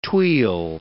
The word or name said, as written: tuille